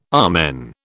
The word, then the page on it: amen: British English